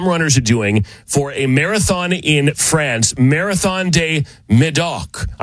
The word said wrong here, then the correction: Marathon du Médoc: I'm sure I said that wrong. Or excuse me, Marathon du Médoc.